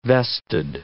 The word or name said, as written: vested